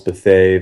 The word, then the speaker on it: Bethea: Um, Twitter is just my name at Charles Bethea.